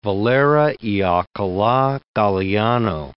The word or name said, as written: Valera (y Alcala Galiano)